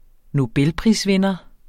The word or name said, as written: nobelprisvinder